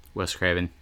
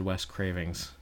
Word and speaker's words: Wes Craven: Wes Craven. – Wes Craven. I almost said Wes Cravings.